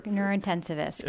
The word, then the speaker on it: neurointensivist: She is a neurointensive doctor, right? Is that right? – Neurointensivist, correct. – Neurointensivist. I missed the syllable there.